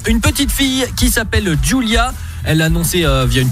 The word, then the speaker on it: Ghjulia: Julia est donc née le 2 novembre 2020 et son prénom s'écrit à la Corse comme son papa. Donc ça s'écrit G-H-J-U-L-I-A. Bienvenue donc à la petite Julia.